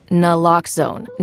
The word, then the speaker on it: Naloxone: For the record, it's Naloxone. Nuh, like, is Naloxone addictive? Nuh. Locks like Naloxone keeps humans safe like locks keep bikes safe, and own like Naloxone can save lives in our very own communities.